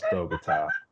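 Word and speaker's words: Bogotá: Bogota, pronounced Bogota, a capital in the clouds.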